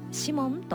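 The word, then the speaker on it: 鰤門: 東京銀座と日本橋にある江戸前寿司のシモン、漢字でお魚のブリという字に建物の門と書いてシモンと読みます。女性一人でも安心して入れるお寿司屋さんです。銀座にあるシモンは銀座駅から徒歩 3分、赤いカウンターで食べる握りは格別です。日本橋にあるシモンは三越前駅から地下直結のコレドー室町 4階、お寿司をワインで楽しめるお寿司屋さんです。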